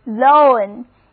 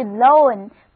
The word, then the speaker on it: loan: L-O-N-E 字母 O 还是发 O 的音， lone，意思是独自的，单独的。它有一个同音词，就是 L-O-A-N，发音同样是 lone，不过意思可大不一样。 L-O-A-N lone 意思是贷款解除，你可要仔细分辨哦。